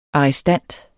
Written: arrestant

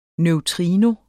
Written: NEUTRINO

